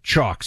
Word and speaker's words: Choq: That's spelled Chalk, C-H-O-Q. Chalk is who you need to check out if you want additional focus, energy, and drive in your day-to-day.